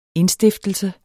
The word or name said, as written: indstiftelse